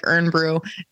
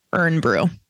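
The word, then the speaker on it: Irn-Bru: It's spelled IRNBRU, Urn Brew.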